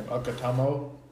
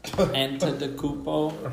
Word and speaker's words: Antetokounmpo: Okotamo. – Yeah. I just go like this when I say his last name. – Antetokounmpo.